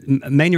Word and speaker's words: Maine: Maine.